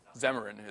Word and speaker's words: Xamarin: So can anybody actually pronounce that? Xamarin, is it? Okay, cool.